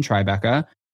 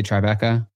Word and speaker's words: Tribeca: Are you going to laugh at how I say Tribeca? – No, you said it. Okay, so no, it's not that you say it wrong. It's Tribeca. – Yeah. – But sometimes you're like, Tribeca.